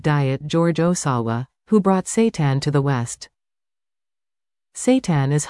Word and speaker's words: seitan: Seitan, pronounced Seitan, is a wheat-based meat alternative that has been around for at least 1,500 years.